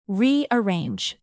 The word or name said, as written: rearrange